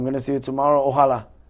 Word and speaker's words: Ojalá: Ojalá.